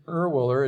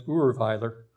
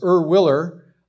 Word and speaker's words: Erwiller: If my ancestors were still living in Switzerland, we'd be pronouncing it Urweiler. It's Swiss. But it's like most names of families have come to America, they've been westernized, I guess you could say it. So it's Erwiller.